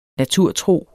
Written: naturtro

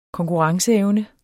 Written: konkurrenceevne

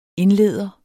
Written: indleder

